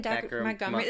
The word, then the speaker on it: Dacre Montgomery: Dacre Montgomery, the guy from Stranger Things.